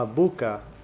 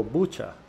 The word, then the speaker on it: Bucha: Y bueno, el programa de 60 Minutes había visitado un pueblo que se llama Buca o Bucha, se escribe B-U-C-H-A, en Ucrania.